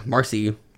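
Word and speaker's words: Maryse: and then like so Marcy. That's how you pronounce it. It's like Mary C is how you write it.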